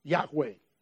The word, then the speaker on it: Yahweh: So you get just the consonants that are then pronounced Yahweh.